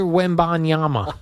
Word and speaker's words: Wembanyama: Victor Wembanyama.